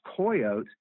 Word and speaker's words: coyotl: And the word was spelled C-O-Y-O-T-L, but the L in the Nahuatl language is a silent L. And so the name would have been pronounced coyote.